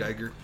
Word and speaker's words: Giger: Or Giger, I don't know how you say his name. – Geiger.